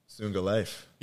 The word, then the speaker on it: Sunga Life: talk about how you got into Tsunga Life.